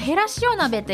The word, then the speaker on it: 減塩: あとね、へらしお鍋って減塩と書いてへらしおと読むんですよね。